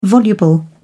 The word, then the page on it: voluble: British English